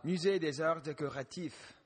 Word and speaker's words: Musée des Arts Décoratifs: Oh, Musée des Arts Décoratifs.